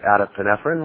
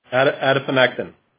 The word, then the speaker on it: Adiponectin: but adiponephrine, was it? – Adiponectin.